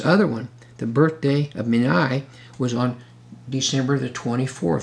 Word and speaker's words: Meni: Now, the word number here is another word in the Hebrew that's pronounced Meni, I guess, M-E-N-I, which was another pagan god.